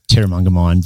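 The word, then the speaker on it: Terramungamine: The most amazing people we're meeting on the road here at Tarungamine Reserve. Did I say it right? – Terramunga mine. Very, very close, Phil.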